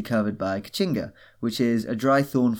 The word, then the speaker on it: Caatinga: So we're travelling to the Brazilian Caatinga, where, so when people think of, it's written Caatinga, but it's pronounced Caatinga.